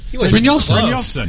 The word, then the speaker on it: Brynjolfson: Okay, you got to show me. Pronounce. – Bryn. Yolf. – Yolf. – Son. – Oh, that's easy. – Just like it looks. – Bryjolfson.